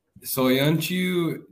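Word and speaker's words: Söyüncü: But I, I, I will flip it back and say, even on a serious note, Soyo is it Soyuncu? Is that, is that how you say his name? – That's correct. Yep. Soyuncu.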